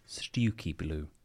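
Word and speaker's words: Stiffkey: However, I'm told it is pronounced Stewky Blue.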